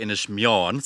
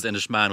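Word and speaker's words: Inis Meáin: Yeah, so I went with Coluch Forbacher Inish Mion. So Inishmion is how I learned to pronounce Inishmion, okay. – Exactly, versus Inishman, which I'd been saying all my life.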